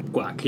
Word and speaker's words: Quoc: Guac, yeah. – Yes. It's spelled Q-U-O-C, but it, guac.